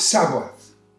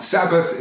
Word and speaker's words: Sabaoth: This military strategy that we're talking about is really held and led by the Lord Sabaoth. it's not Sabbath, it's spelled S A B A O T H.